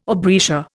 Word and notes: aubrietia: Indian

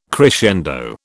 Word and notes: crescendo: British English

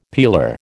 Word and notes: peeler: American English

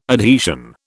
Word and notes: adhesion: British English

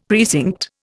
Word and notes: precinct: Indian

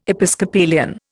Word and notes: Episcopalian: Indian

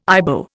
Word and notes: Ibo: Indian

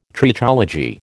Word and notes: Trichology: American English

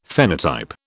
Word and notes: phenotype: British English

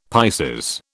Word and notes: Pisces: British English

